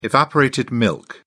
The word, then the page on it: evaporated milk: British English